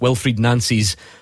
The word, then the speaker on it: Wilfried Nancy: What have you made of Wilfried Nancy? He says that's how you say it. Wilfried Nancy's first press conference.